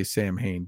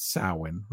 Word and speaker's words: Samhain: It says, the horror started on the eve of, and we know it's, we all say Samhain, but it's Samhain, right? It's supposed to be pronounced Samhain. – Okay.